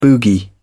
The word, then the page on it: boogie: British English